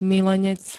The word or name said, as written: milenec